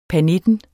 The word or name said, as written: pernitten